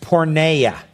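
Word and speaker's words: porneia (πορνεία): Now you must understand that the term here for immorality is a Greek term uh, that we um, uh, that is pronounced porneia.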